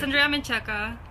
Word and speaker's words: Andrea Menchaca: It's Andrea Menchaca.